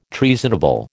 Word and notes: treasonable: American English

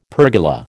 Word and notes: pergola: American English

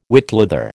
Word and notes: whitleather: American English

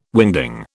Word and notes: wingding: British English

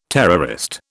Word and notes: terrorist: British English

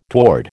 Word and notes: toward: American English

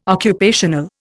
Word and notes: occupational: Indian

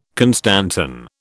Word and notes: constantan: British English

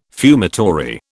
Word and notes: fumitory: British English

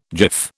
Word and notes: jiff: British English

